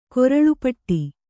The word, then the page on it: ಕೊರಳುಪಟ್ಟಿ: koraḷu paṭṭi; kannada